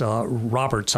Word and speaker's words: Robards: And then after the fellow whose name was, it's pronounced Roberts, I'm told, though it's actually spelled R-O-B-A-R-D-S, like Robarts.